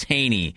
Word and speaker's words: Hejny: Haas Haney. The it's spelled H-E-J-N-Y, but the J is silent, Michael, just for you to know.